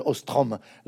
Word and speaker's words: Ostrom: Et puis vous abordez, aussi les travaux de d'Elinor Orstrom qui a été je prononce mal, Ostrom,